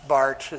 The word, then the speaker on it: Barth: Not Barth, it's Barth. It's German pronunciation.